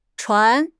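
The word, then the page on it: chuan2: tone 2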